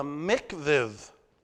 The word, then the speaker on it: mikvah: It's called, and let me get the pronunciation right, it's called the mikvah. The mikvah.